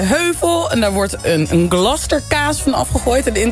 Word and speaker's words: Gloucester: Je hebt in Engeland, in Gloucester. Ik heb op deze uitspraak geoefend. Want het is zo'n Gloucester naam. – Ja, precies. Net zoals Worcestershire.